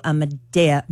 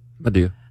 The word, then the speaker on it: Madea: But he's also an actor who was in Boo Madea. Halloween. I always say it wrong. – Madea.